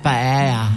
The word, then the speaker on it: paella: Actually, paella daw yan. Hindi paella, ha? Ang pronunciation, paella. Walang elia. Hindi paella, kundi paella. Kaya nga parang naloka sa akin si Meryl Soriano. Paella siya ng paella. Sabi ko, it's not paella, it's paella. Talaga?